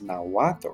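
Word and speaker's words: Náhuatl: The Mexica language or the Aztec language, Nahuatl, even though there's a TL at the end, it's Nahuatl, or how we often say the god Quetzalcoatl, with the TL at the end, the L becomes silent, so it's Quetzalcoatl.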